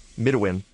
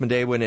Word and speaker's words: Midewin: So the thing about Medewin, and by the way, if folks listening, you should know it's spelled Midewin. And people will look at it and say it's Midewin. But it's pronounced Medewin, and it's kind of like the secret handshake among environmentalists.